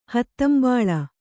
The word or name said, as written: ಹೂದಂಬುಲ